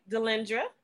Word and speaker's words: Delendra: Delendra, which is D-E-L-E-N-D-R-A. Tarsavina, T-A-R-S-A-V-E-N-A.